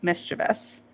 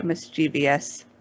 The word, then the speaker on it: mischievous: One, which is probably the biggest one, is mispronouncing mischievous. People who say mischievous. – And you don't like mischievous people. – Because it's spelled, it goes, comes from mischief. Mischief, yeah. Mischievous, that's it.